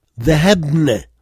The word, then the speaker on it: ذهبنا: On écrit le alif mais on ne le prononce pas. ذهبنا, ذهبنا, ذهبتم, ذهبتن, ذهبوا, ذهبنا.